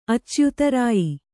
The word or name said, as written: ಅಚ್ಯುತರಾಯಿ